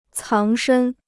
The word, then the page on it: 藏身: cáng shēn